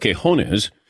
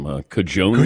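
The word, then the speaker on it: Quiñones: And then there was the controversy on the pronunciation of Quejones, and of course, Ross Tucker called him Cajones.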